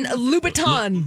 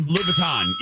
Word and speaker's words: Louboutin: That would be Christian Louboutin. – Louboutin, yes.